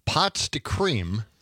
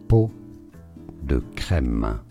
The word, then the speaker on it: pot de crème: French in France, it is said as peau de crème. Peau has a silent T. Peau de crème. – Pot de crème. You know, that sounds really good. – And pot is P-O-T-S, so the French just decided S's don't matter because they couldn't say them what? – I don't know.